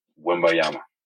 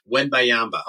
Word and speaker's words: Wembanyama: Wembanyama. – I cut off a syllable and I say Wenyama. – Yeah, you missed the other one.